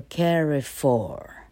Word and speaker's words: Carrefour: 啊，famine，你看 fa 是重音哦，family fa 是重音哈，animal a 是重音，OK 哈，连家乐福哈，家乐福是法国公司，所以这个字 suppose 是法文哈，呃，法文的话念作 Carrefour，Carrefour 哈，那它既然拼成老客，既然是英文平台了哈，那我就勉勉强用英文来读法文，叫做 Carrefour，Carrefour 哈，Carrefour 念作 Carrefour，重音在第一节，它不会跑到后面去了。